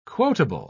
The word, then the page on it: quotable: British English